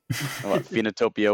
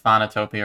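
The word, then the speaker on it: Phoenotopia: Phenotopia Awakening? – There you go, thank you. I keep wanting to say like Phonotopia or Phonotopia. – Think Phoenix. – Phoenix, got it. Phenotopia.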